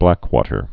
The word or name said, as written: blackwater fever